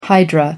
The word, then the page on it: hydra: American English